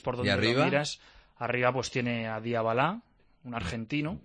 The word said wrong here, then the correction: Dybala: Dybala. – No, es que es Dybala, me parece que se pronuncia.